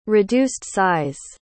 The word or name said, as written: reduced-size